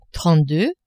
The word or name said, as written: trente-deux